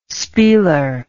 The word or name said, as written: spieler